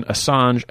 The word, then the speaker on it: Assange: How do you pronounce his Assange. – Name? – Assange.